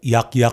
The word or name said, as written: Yakyak